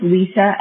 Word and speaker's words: Lisa: So my name is Lisa, L-I-S-A. Herring is my last name, and I'll spell it. It's H-I-E-R-I-N-G.